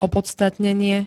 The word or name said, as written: opodstatnenie